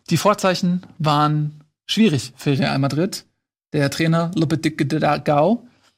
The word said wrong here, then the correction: Lopetegui: äh, Lopetegui, wie spricht man das aus? Lopetegui, muss man sich gar nicht merken, ist eh weg.